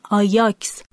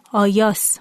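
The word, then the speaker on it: Ajax: کلمه آژاکس که به زبان یونانیه در زبان لاتین آیاکس تلفظ میشه و اگه دوباره از زبان لاتین به یونان برگرده تبدیل به آیاس میشه.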